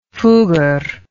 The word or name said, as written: fugger